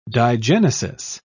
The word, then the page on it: digenesis: British English